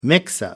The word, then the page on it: mix-up: American English